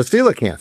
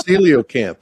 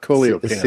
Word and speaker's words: coelacanth: As a coelacanth. Very good. Yeah, I'm impressed. – Oh, hey, there you go. I did another Fred pronunciation. So it's coelacanth, not coleocanth. – Coelacanth, yeah.